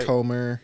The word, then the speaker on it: Jodie Comer: I don't know if Jodie I say it right. – Jodie Comer.